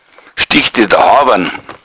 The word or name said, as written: hawan